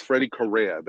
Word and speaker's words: Freddy Correa: Yes, Freddy Correa.